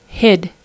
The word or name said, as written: hid